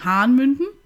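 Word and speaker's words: Hann. Münden: Hanmünden? – Äh, äh, ja. Hanmünden? Ja, ja, ja. – Es verschiedene Betonungen bei so einer Tanzschule. Die einen sagen Hanmünden, die anderen sagen Hahnmünden.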